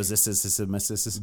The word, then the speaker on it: Zosimus: So Zosimus.